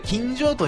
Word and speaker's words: 今上: これ今上と読むんですよ。今上という風にね、昭和天皇独白録の回では私読んじゃってたんですけど、これ今上と読むらしいですね。